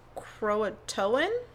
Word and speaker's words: Croatoan: but Croatoan? – Exactly, yeah.